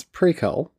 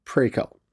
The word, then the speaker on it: Prequelle: Their most recent album, Prekel, which I finally decided to pronounce Prekel, simply because in interviews, that's how they say it, and that's the way I'm going with it. Prekel.